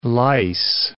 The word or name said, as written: lice